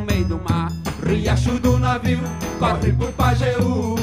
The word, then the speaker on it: Riacho do Navio: Riacho do Navio.